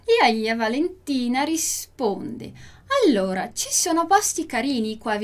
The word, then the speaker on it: allora: Allora, lembra que a gente já falou sobre isso, que a gente vai começar muitas frases no italiano com allora, allora, com le due L, allora. Então, atenção aí na pronúncia dos dois L's.